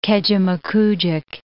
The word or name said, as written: Kejimkujik National Park